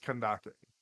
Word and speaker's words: Candace: But people would understand, because that's, like, sure, it's spelled Candace, but think about where she's from. She's from an entirely different region. No one in America is going to look at frickin' the Egyptian-based Sumeru Desert and be like, oh yeah, it doesn't make sense that her name is Kandake, even though it's spelled like Candace would be.